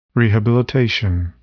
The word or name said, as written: rehabilitation